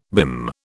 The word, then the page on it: BIM: British English